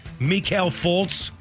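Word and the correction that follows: Markelle Fultz: Markel Fultz. – Yeah, I know. I love butchering his name.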